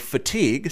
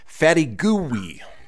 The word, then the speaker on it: fatigue: You have Twitter user Quinn Van De Keer, who pronounced the word fatigue, spelled F-A-T-I-G-U-E, as fatty gooey.